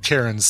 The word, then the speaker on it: Cairns: We pronounce it Cairns, C-A-I-R-N-S.